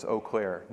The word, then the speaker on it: Eau Claire: Is that how you say Eau Claire? Okay, because it's spelled Eau Claire, right? Or like Eau Claire, but it's Eau Claire. That's how y'all pronounce it? – Yes.